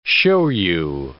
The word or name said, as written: shoyu